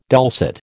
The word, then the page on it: dulcet: American English